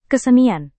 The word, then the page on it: kesenian: indonesian